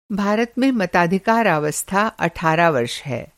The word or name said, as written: मताधिकारावस्था